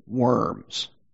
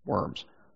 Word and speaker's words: Worms: When we read that, by the way, looks like, or it says worms, okay, W-O-R-M-S. But in German, it's pronounced Worms. It's the name of a city.